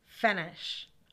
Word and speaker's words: Fenech: Vince Fenech. I don't know how to pronounce his last name. F-E-N-E-C-H. – E-C-H, Fenech.